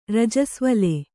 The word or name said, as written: ರಜಸ್ವಲೆ